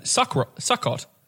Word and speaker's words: Sukkot: Sukkot. S-U-K-K-O-T.